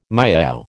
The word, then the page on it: miaow: American English